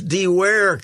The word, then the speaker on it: Dewar: Who's this DeWare character? – Dewar? – Dewar? – Connor Dewar, who is a fast... – So we pronounce it like Scotch whiskey, then? – Yes.